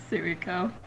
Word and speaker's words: Suiko: Arata, Izuto, Izuto, and Su- how do you say it? – Suiko. – Suiko. And Suiko, get up here.